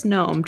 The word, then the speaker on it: Nome: It is gnome, just not spelled like you think it is.